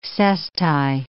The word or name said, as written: cesti